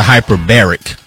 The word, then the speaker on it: hyperbaric: No. That's a hyperbaric. That's a whole different word, a whole different term, a whole different part of the language. Hyperbaric chamber. It's not hyper. Your syllable pronunciation was wrong.